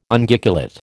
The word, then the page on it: Unguiculate: American English